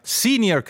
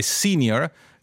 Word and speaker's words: senior: In italiano molti dicono junior e senior con una pronuncia adattata derivata dall'inglese per due parole che sono latine che si pronunciano evidentemente junior e senior. E quindi la parola è latina, la pronuncia del latino in questo caso è molto simile all'italiano o meglio il contrario, e quindi perché dire junior e senior?